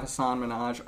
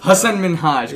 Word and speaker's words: Hasan Minhaj: Ladies and gentlemen, we're honored to have Hasan Minhaj on the show tonight. Did I say it right? – No, I say Hasan Minhaj. – Hasan Minhaj. Yeah. Gotta say it the Desi style.